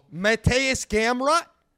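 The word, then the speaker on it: Mateusz Gamrot: Mateus Gamrot? – Yeah, Gamrot, yeah.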